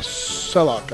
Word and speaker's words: Selok: How do you say her name? Or his name? S-E-L-O-K? – Selok, I think. – Selok. Will you please get your information to Jethel?